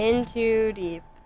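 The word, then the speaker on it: N 2 Deep: It's called N Too Deep. – In Too Deep. – In Too Deep. It's an N. – Yeah, I know, but like, it's pronounced in.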